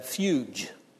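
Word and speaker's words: fuge (φυγή): Well, we make a soft G. Greek doesn't have a soft G. And we have fuge instead of fugue.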